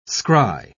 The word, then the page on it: scry: British English